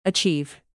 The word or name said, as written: achieve